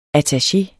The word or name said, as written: attaché